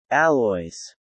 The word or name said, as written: alloys